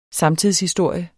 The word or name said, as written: samtidshistorie